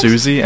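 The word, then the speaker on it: Siouxsie: Anyway, here is Spellbound by how do you pronounce Susie and the Banshees. – That does not look like it would be pronounced as Susie. – It is.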